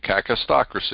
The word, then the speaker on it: kakistocracy: But we live in a cacistocracy, and you spell it K-A-K-I-S-T-O-C-R-A-C-Y, cacistocracy.